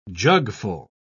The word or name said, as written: jugful